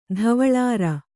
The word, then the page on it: ಧವಳಾರ: dhavaḷāra